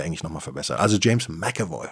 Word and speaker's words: McAvoy: Ach, den, äh, James, James, James McAvoy. So spricht man es britisch aus. McAvoy. Nicht etwa McAvoy. Die Deutschen wollen das immer sehr korrekt aussprechen, sagen das Mac immer, aber macht man eigentlich im Englischen nicht.